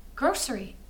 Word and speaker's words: grocery: Grocery. That's what... – Oh, there's a sh in there and it's... – I feel like you say it how it's supposed to be pronounced, though.